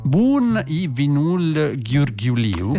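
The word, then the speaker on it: Bun e vinul ghiurghiuliu: Il titolo è Bun i Vinul Ghiurghiuliu. – Esattamente così, proprio la pronuncia è questa.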